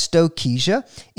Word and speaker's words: Stokesia: He says, I prefer to pronounce Stokesia as Stokesia in recognition of the man who found it, Dr. John Stokes, for whom the genus was named.